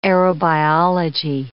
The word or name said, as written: aerobiology